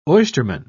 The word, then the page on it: oysterman: British English